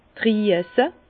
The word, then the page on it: d' Drüese: Bas-Rhin; Herrlisheim